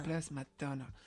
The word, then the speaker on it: The Blessed Madonna: Euh, The je sais pas comment, tu le prononces ça The Blessed Madonna. – Blessed Madonna.